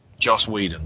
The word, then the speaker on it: Joss Whedon: mainly through the direction and the writing of Jess Weldon. I never get his name right. Help me out here. – Joss Whedon. – There we go.